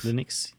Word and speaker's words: Linux: Linux? – Linux, yeah.